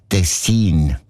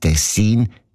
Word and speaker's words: Tessin: Also klar, Tessin. Aber der SRF-1-Hörerin Regula Stern-Grieser von Locarno ist aufgefallen, dass gewisse Leute statt Tessin Tessin sagen. Also Betonung auf ein Meh mit einem kurzen I.